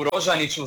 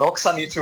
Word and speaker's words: Rožaniću: Snimio sam spot u vinariji, u Rožaniću, za one koji vole izgovarati krivo Roksaniću.